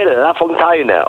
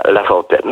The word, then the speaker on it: Lafontaine: Ktoś tam powiedział, panie Lafontaine. On mówi, nie, to jest francuskie nazwisko i się je wymawia Lafontaine.